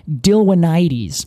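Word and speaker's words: Dilwynites: So in this case, they have found a bunch of pollen from a species they call Dilwinides. – Dilwinides. – Dilwinides. And that's spelled Dilwinites.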